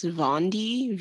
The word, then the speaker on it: Vaundy: And then another Japanese artist, I think it's pronounced Vondy, V-A-U-N-D-Y.